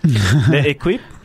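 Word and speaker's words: L'Équipe: I'm going to butcher this. L'Equipe? – Yes.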